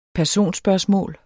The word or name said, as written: personspørgsmål